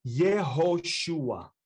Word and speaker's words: Yehoshua: Well, Joshua in Hebrew is Yehoshua.